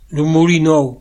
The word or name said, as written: Moulin Neuf